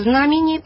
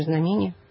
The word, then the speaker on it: знамение: Да, так же, как и знамение по-церковно-славянски, а мы говорим знамение.